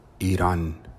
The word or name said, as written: Iran